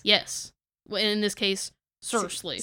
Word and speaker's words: SRSLY: So, and when we say it's called Seriously, it's spelled S-R-S-L-Y. – Yes. – So, Seriously.